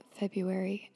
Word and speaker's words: February: because every time I try to say it with the R in there, it just comes out really weird, and I'm just going to say it, February.